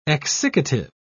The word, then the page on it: exsiccative: British English